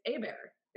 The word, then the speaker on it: Hébert: The one that always sticks out for me, and I love this family, is it is actually spelled Hubert, but in Louisiana, it's Hébert is how they pronounce it.